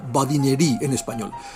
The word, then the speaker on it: badinerie: Badinerie se escribe badinerie y solemos pronunciar en, bueno, castellanizado, badinerie en español.